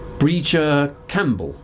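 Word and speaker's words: Brìghde Chaimbeul: This is Breeger Campbell, and helpfully the End of the Road site has given me pronunciation guidance there, because it's spelled B-R-I-G-H-D-E and the surname C-H-A-I-M-B-E-U-L, and she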